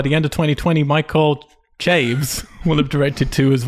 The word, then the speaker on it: Chaves: I put Mike Chavez. – I think you're fine if the man you're speaking of is who I think it is. It's pronounced Chaves, but all right.